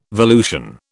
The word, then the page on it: volution: British English